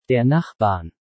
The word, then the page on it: Der Nachbarn: German